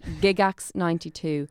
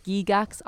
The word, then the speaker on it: Gygax: There are different ways to pronounce it. Some people say Gygax. Some people say Gigax, I say Gigax.